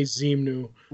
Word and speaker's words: Zemnu: I can't pronounce the replacement Hulk's name. – What do you mean? The replacement? – The big white guy, the big white furry guy. Like, oh, Zemnu? Say Zemnu.